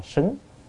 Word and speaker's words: xứng: Ở đây do theo cái chú giải Hòa Thượng á, thì căn cứ ở đây phải đọc là xứng, xứng tức là thích hợp.